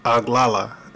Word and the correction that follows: Oglala: I'm not sure if I'm pronouncing this right. – Say it again. – O G L A L A. Oglala.